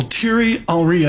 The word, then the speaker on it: Thierry Henry: Thierry Henry, I hope I'm pronouncing that correct, you did.